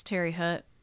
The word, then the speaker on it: Terre Haute: As I said, we're in Terre Haute, and that is like not even kind of how you pronounce Terre Haute, but we're also not bougie French people, so we are Midwestern. – It's Terre Haute, so Terre Haute.